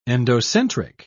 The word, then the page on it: endocentric: British English